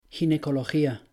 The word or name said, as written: ginecología